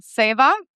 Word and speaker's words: Seva: Where your favorite local restaurant is an Indian place called Seva. – That's actually correct. And you pronounced it correctly.